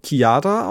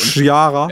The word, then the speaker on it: Chiara: Und dann haben wir noch von, das muss ich direkt schicken, weil es sehr schön war, von, spricht man eigentlich eine C-H-I-A-R-A Chiara aus? Weil es gibt ja Leute, die sagen Chiara und das finde ich ganz schlimm.